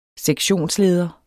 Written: sektionsleder

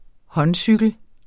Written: håndcykel

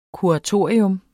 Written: kuratorium

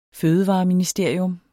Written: fødevareministerium

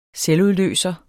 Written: selvudløser

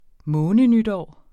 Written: månenytår

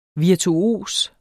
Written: virtuos

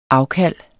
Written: afkald

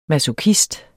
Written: masochist

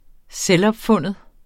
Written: selvopfunden

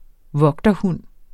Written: vogterhund

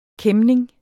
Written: kæmning

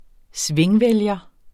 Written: svingvælger

